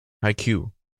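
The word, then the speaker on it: Haikyu: I wanted to mention, am I saying this right? Haiku? Am I saying that? The volleyball anime? – Haiku. – With a Q.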